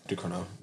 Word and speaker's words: Ducournau: And then my number one, Julia Ducournau.